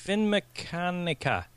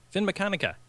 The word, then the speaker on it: Finmeccanica: Finmeccanica. Finmeccanica. – Yeah. – There we go. – We'll go with that. Let's go with that. Yeah. Finmeccanica, $17.2 billion.